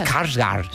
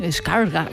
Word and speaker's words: Skarsgård: Skarsgård. – Sí, Skarsgård.